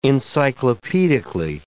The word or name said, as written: encyclopedically